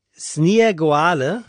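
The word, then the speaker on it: Snieguolė: Ihr zweiter Name, den spricht man aus, ich habe es mir irgendwo in Lautschrift aufgeschrieben und finde es jetzt natürlich, auf die, doch hier, spricht sich aus Sniegoale, mhm, und das heißt Schneewittchen auf Litauisch.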